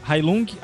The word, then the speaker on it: Heilung: Heilung, H-E-I-L-U-N-G.